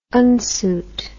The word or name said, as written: unsuit